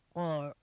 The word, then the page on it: koorl: Noongar